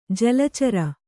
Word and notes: ಜಲಚರ: jala cara

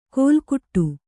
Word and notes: ಕೋಲ್ಕುಟ್ಟು: kōlkuṭṭu

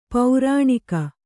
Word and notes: ಪೌರಾಣಿಕ: kannada

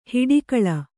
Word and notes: ಹೊಡೆಗುಳು: Kannada

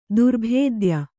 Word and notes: ದುರ್ಭೇದ್ಯ: Kannada

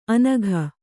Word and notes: ಅನಘ: Kannada

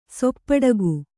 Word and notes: ಸೊಪ್ಪಡಗು: soppaḍagu

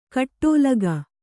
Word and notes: ಕೋಟಲಿಗ: kōṭalia; Kannada

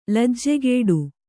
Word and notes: ಲಜ್ಜೆಗೆಡು: lajjegeḍu

